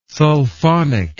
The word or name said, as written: sulfonic